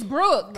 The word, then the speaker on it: Brooke: My name is Brooke. – Right. It's not Brooke. It's not hard. It's not even 2 syllables.